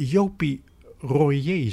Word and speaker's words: Jopie Royer: En nu een boek over Jopie Royer, zeg ik dat goed? – Ja, helemaal correct.